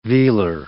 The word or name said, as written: vealer